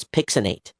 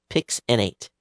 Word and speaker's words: PXN8: And the way it's pronounced, it's Pixinate. Pixinate.